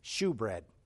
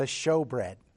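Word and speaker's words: shewbread: I pronounced it shoebread because it's spelled S-H-E-W. But the showbread.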